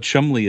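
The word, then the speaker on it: Cholmondeley: It's Q-U-A-T-E-R. Quatermass. – But Chumley is spelled Chalmondeley. – Yes.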